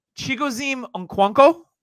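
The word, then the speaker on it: Chigoziem Okonkwo: He was the most athletic tight end at the combine, and I'm going to totally butcher his name. Chigozim Okonkwo from Tennessee. – That's pretty good.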